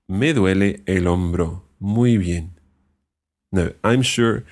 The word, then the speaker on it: hombro: We're going to learn one more word, and that is el hombro. – El hombro. – El hombro. Now notice that hombro starts with an H. It's a silent H. El hombro.